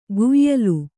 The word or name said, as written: ಕುಯಲು